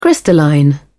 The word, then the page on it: crystalline: British English